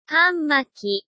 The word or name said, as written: hanmaki